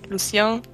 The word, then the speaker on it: Lucien: Sind dabei auf die Dame des Waldes gestoßen und haben ja auch Lucien, Luz, wie spricht man ihn denn aus? Lucien kennengelernt und ein bisschen über meine Geschichte erfahren und darüber, dass die beiden Soldatinnen leider gestorben sind.